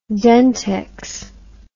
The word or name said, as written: dentex